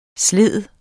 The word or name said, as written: slide